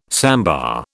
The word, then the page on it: sambar: British English